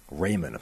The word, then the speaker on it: Raimann: They're probably not reaching for a guy like Bernard Raimann or Raymond, apparently, is how that's pronounced, even though that's clearly not how it's pronounced if you have any kind of German heritage, i.e. being Austrian, or Trevor Penning.